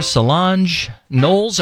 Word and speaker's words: Solange Knowles: Beyonce's sister, Solange Knowles.